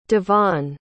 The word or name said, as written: devonne